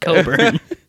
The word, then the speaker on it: Cockburn: C-O-C-K-B-U-R-N. Yeah. It's spelled Cockburn. Yeah. It is spelled Cockburn, but it's pronounced Coburn.